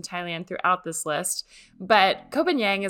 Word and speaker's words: Koh Phangan: It is K-O-H is the first word, and then P-H-A-N-G-A-N. So it's spelled a little differently than it sounds.